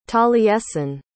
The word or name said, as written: taliesin